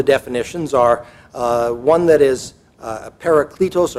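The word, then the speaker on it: parakletos (παράκλητος): I'm going to guess many of you have heard that word before, parakletos, but I'll spell it. It's P-A-R-A-K-L-E-T-O-S, parakletos, sometimes also paraclete.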